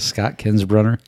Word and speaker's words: Kinsbrunner: Yes, Scott Kinsbrunner.